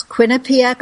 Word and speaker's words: Quinnipiac: Nodder School of Medicine at this town that I'm going to try to pronounce, Quinnipiac.